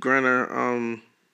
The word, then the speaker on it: Griner: Last but not least, Brittany Griner. That's how you pronounce her name? Last name, Griner? – Griner.